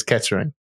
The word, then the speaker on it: Kettering: And you can tell because we don't pronounce our T's properly, okay? So most people look at it as Kettering.